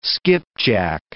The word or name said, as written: skipjack